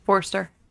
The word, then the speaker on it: Forster: Did I say it right? – Wrong? No. – Robert Forster. – Forster.